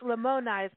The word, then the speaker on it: Lamoni: And by the way, so it's Lamoni. So again, I'm wrong in the pronouncing.